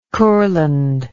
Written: Kurland

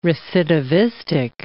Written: recidivistic

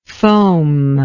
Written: foamlike

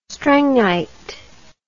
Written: strengite

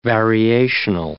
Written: variational

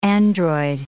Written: android

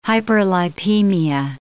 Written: hyperlipemia

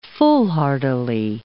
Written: foolhardily

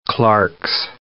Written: Clark's nutcracker

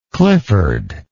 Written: clifford

